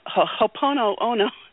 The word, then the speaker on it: Ho'oponopono: the Ho'oponopono. – Ho'oponopono.